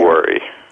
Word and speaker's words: Ostowari: Ostowari.